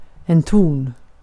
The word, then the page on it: ton: /tɔn/; /tuːn/